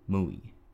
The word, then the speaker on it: Mui: I shall pronounce Wu Wei in the Japanese style, Mui.